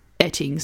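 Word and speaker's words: Oetting: I've also heard her surname pronounced Etting, but I think it's Oating.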